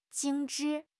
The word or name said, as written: 精致